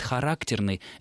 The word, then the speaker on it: характерный: Характерный, может, это более литературное слово, которое обозначает специфически присущий тому или иному предмету, а вот характерный — это как раз пример театрального жаргона, театрального сленга. Характерный обычно считается актер или характерная роль, и вот предполагается, что лучше это слово с таким ударением сохранять исключительно на театральных подмостках.